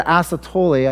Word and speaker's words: asystole: She had an asystole, I think I said that right.